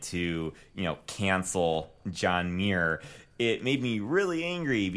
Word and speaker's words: Muir: John Muir. I think that's how you pronounce his last M- name. Muir. – Uh, m U I R. – Muir. – Muir. Uh, no, Muir. Uh, Muir. Let's say Muir.